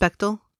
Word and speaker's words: Bechdel: Did I say that wrong? Bechdel test? – Bechdel. – Bechdel Myers.